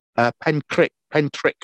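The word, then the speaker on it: Pentrich: Pentrick, as in Crick.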